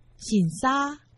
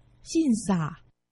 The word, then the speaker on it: 新社: 在今天节目中介绍客家文化重点发展区台中市新社区，客家话四线腔发音醒沙，海陆腔发音信撒，欢迎听众朋友们一同收听。